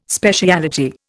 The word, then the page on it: speciality: Indian